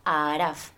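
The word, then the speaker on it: Al Aaraaf: Eh, el público em, al Araf, o sea, no, lo pronuncio mal, es que es Araf, con dos as y con dos as también, al Araf.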